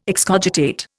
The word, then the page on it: excogitate: Indian